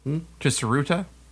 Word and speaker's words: Tsuruta: More than 30 bald men gathered at a hot spring facility in Tsuruta City, Japan. Did I say that right? – Yes. Heard about this. – Tsuruta? T-S-U-R-U-T-A City in Japan? – Tsuruta City.